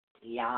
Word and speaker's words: Yam: Now, the seat sound is pronounced YAM, is what it's pronounced.